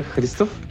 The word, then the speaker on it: Hristov: Uh, yeah, so my name is Dobromir Hristov, or you can just call me Dobri because it's shorter.